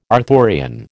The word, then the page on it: Arthurian: American English